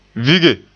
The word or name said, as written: vige